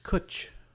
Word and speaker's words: cwtch: It's kutch. That's the word. It means hug. – What? – It's spelled C-W-T-C-H.